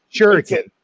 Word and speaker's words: Shuriken: Shuriken. – Actually, it was the Comico part. It's Comico. – I always do that. But, man. – Yeah, everybody pronounces it Comico. But Shuriken, you pronounced that correctly.